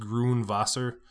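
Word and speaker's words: Gurnwasser: I believe it's pronounced Grunwasser, and it's spelled G-U-R-N, and then W-A-S-S-E-R, Grunwasser.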